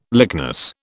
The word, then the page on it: lychnis: British English